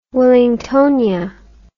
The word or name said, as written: wellingtonia